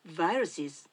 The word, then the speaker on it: viruses: ウイルスを英語で言う時は、 viruses、 V で始まる発音で、しかもこの名詞は大抵複数で使われます。